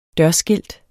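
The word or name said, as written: dørskilt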